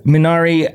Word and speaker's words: Minari: I'm going to make sure I'm saying it right. Minari. – Yeah, Minari. I no judgment if anybody says it's Minari, but like how it's like asking.